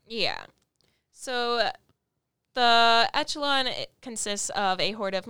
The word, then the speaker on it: Echelon: I thought it was Echelon, but it is Echelon.